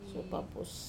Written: suopapus

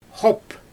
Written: xoop

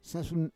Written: sasun

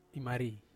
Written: mari

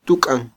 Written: tuk'an

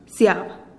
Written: siab

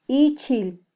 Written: ichil

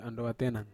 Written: andowa tenan

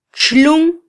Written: txlun

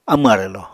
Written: amw arwlò

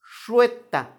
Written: xueta

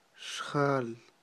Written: xjal

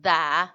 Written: daa

